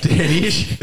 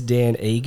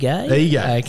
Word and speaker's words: Ige: it's not Dan Ish. it's Dan Ige. – Ige. – Okay. It's a very hard one to pronounce when there's only three letters in your uh, in your surname, but yep.